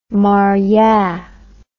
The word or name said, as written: moryah